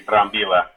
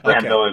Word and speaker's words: Brambila: Yeah, if you want to get fancy, Brambilla. But Brambilla okay. Is just fine.